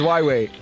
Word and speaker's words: Huawei: I don't know if it's Huawei or Huawei. – Your second attempt was right. – Huawei. No, I can't pronounce that word. – It's Huawei.